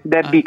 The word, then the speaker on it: Debbie: A come Antonio o Debbie perché in inglese si legge? – No, è perché in inglese Debbie Carri, quindi...